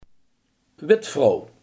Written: weduwe

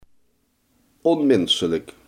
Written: onmenselijk

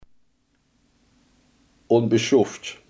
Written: onbeschoft

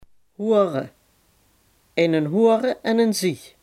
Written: mannetjesduif

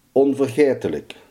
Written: onvergetelijk

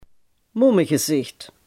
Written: carnavalsmasker

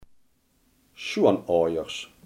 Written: schoonouders

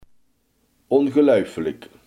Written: ongelofelijk

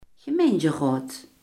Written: gemeenteraad

